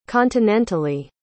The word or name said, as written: continentally